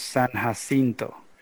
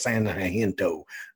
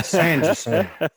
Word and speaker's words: San Jacinto: Hey, and it's pronounced San Jacinto. – Yeah, you know that before. – So if you guys are going to give me crap about this stuff being pronounced in English, I'm going to give you crap before we won our independence from Santa Ana, it was San Jacinto. Okay, but now San Jacinto.